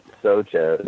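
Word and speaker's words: Soča: Yep, Socha. It's spelled like S-O-C-A.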